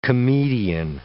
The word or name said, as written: comedian